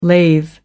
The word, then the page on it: lathe: American English